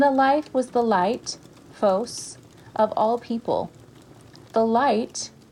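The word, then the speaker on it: phos (φῶς): When I hear the word the Force from the movie, it brings to my mind a Greek word with similar pronunciation, the phos, which means the light.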